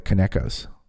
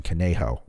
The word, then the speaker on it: Conejo: We were talking about how it's become the mass now are the Kanekos of the fraidy cat liberals. The Kaneko is the mispronunciation of my 3-year-old saying Conejo, which is the Spanish word for bunny, for his little bunny that he used to sleep with until when he turned about 3, then he didn't need it anymore.